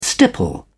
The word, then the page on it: stipple: British English